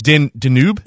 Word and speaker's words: Danube: Raised in Simsbury, Connecticut, Jeff Lizotte is a Cornell graduate whose culinary resume includes experience at David Boulay's, how do you say that restaurant? Danube? – Like the river.